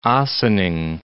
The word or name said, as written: Ossining